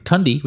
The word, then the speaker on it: Thandi: Tandy Newton. – Thandi. – How do people say her name? – Tandy. Tandy. – Tundi, which means cold in our language.